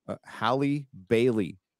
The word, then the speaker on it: Halle Bailey: I'm going to call her Halle Bailey, not, yeah.